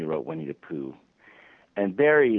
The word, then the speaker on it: Barrie: That's how you pronounce Barrie. It's Barrie, right? Or not Bede.